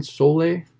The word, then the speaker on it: Soleil: Soleil? – Or how Soleil do you spell Lofts. It? Yeah, S-O-L-E-I-L.